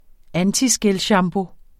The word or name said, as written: antiskælshampoo